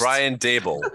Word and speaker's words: Daboll: Doble. How do you want that one pronounced? – Brian Dable.